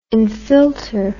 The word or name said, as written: infilter